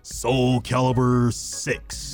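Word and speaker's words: Soulcalibur: Say it right. Soul Calibur Six. That's better.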